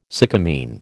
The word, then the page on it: sycamine: American English